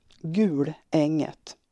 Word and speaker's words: Gulänget: Uttalsbeteckningen så står det ett långt utdraget U och ett tjockt L. Gulänget.